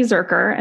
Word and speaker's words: Zuercher: So, I'm on Instagram at Carly Zurker, and my name is spelled CarlyZurcher.